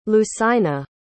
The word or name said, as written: lucina